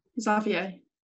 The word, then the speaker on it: Xavier: How do I pronounce it? – Xavier.